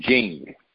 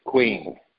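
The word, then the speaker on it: Qing: It's Q, I-N-G, but you pronounce it Jing. – Gotcha. – We pronounce it queen.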